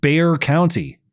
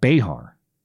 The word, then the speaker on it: Bexar: It's pronounced Bear County.